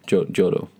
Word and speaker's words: Joro: Jorah? – Yeah, Joro. – In Japanese, but J-O-R-O, spider.